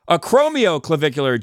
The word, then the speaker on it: acromioclavicular: And I rhymed it, but now I said it wrong. I called it acromicular. What was the rhyme? It was a tear of sadness swept the nation, acromioclavicular joint dislocation. But it's I should have said acromioclavicular joint dislocation.